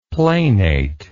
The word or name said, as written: planate